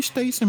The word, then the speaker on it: Stacy: É, na verdade, eu acho que nem pronuncia Stacy, porque é S-T-E-A-C-Y. Eu não sei como é que pronuncia, João. Que é o Stacy com E antes do S. Seria Stacy mesmo.